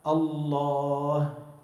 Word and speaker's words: الله: هذه الألف تلفظ ولا تكتب. كيف بتكتب لفظ الجلالة؟ ألف لام لام هاء. تكتب شيء بين اللام واللام ألف؟ ما بتكتب، لكن بتلفظها، بتقول الله.